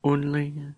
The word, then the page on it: unli: Lwaitel